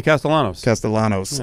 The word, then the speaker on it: Castellanos: Castellanos? – Tommy Castellanos.